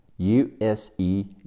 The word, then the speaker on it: use: 动词使用、运用，use u s e use，字母 u 发字母音 u，s 发 z，e 结尾不发音。